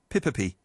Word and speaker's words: Pipipi: How do you pronounce this one? Wow. Why is it such a... There's only two letters in it. And I'm struggling. Pippipi. The Pippipi. The Pippipi. Is a bird name. Pippipi. P-I-P-I-P-I. Okay.